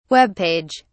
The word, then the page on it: webpage: British English